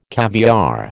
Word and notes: caviare: American English

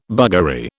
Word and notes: buggery: British English

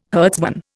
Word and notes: herdsman: Indian

